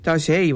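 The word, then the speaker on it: Taizé: Zeg ik dat goed? Taze. – Nee, Taze. – Taze, wat is Taze.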